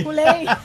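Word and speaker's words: Couleé: Esta muchacha se llama Shea Coulee, no sé, no, le destruí el nombre. Shea Coulee, whatever. Shea Coulee. – Coulee. – Coulee. Se escribe Coulee.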